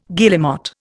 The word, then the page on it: guillemot: Indian